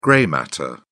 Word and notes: grey matter: British English

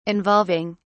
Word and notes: involving: British English

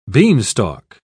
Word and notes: beanstalk: British English

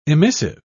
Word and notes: emissive: British English

